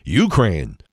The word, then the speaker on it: Ukraine: What is the deal with people, some people calling it Ukraine, Ukraine? It's not, it's Ukraine, accent on the second syllable.